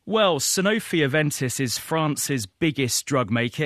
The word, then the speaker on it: Sanofi Aventis: The French pharmaceutical giant Sanofi Aventis. Did I say that right, Steve? – You said it right. Sanofi. – Sanofi Aventis. – That's right.